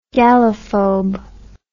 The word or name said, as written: gallophobia